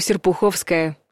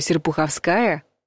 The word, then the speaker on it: Серпуховская: Метро Серпуховская – правильный вариант. Это сложная станция московского метрополитена. Произнести можно минимум тремя способами. Серпуховская, Серпуховская и Серпуховская. Правильный вариант, как водится, один, с ударением на «а».